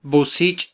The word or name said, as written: bosich